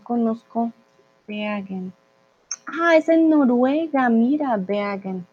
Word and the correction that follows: Bergen: Ay, lo estoy pronunciando alemán. Bergen. Sería Bergen. Hmm, en español. ¿Cómo se dice en español? Un momentito. Bergen. Sí, se dice Bergen.